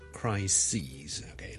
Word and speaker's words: crises: 上一集讲的最常见的例子就是 crisis 啦，C-R-I-S-I-S 一个危机啦，a big crisis 啦，但系 number of crises，OK，、 okay? 留意个发音啊，串字当然系唔同啦，但个发音系唔同嘅，crisis 同 crises。